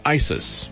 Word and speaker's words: ISIS: I want to talk to Wayne about ISIS. You know, it rhymes with crisis.